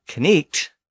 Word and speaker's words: knecht: Now note that knecht is pronounced with a K sound at the beginning. So the modern spelling of knight, K-N-I-G-H-T, reflects the fact that the K was once pronounced.